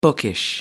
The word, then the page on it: bookish: American English